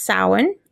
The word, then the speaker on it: Samhain: And then the fourth one is Samhain, which looks like Samhain, but it is pronounced Samhain, and that's also known as Halloween in October.